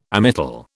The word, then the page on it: Amytal: British English